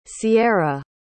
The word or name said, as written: cierra